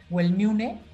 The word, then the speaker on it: Wellmune: Por otro lado, hay ingredientes como el Wellmium. Este ingrediente. – ¿Wellmium se llama? – Está con W y Wellmune se escribe.